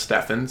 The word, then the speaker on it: Stefan: So for starters, we got Stefan Remmler, who, by the way, got confirmation that it's pronounced Stefan, so...